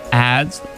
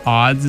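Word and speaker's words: Adz: And before that was Sufjan Stevens, Now That I'm Older, from the 2010 album Age of Ads. Odds is how he pronounces it, but it's spelled ads, so I don't know.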